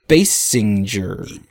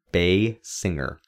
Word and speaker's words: Basinger: It's Basinger. – Basinger. – Oh, Basinger. – Like a singer that exclusively sings at the bay. A bay singer. Basinger.